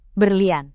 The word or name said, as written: berlian